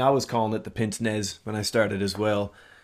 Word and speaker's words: pince-nez: Just so I don't look like an ass coming up in the next couple of minutes, how do you pronounce it? Is it pince-nez or pince-nez? – You're closer with the second one. It is pince-nez. – Pince-nez. Okay. – Yeah. You got to think pince-nez. French.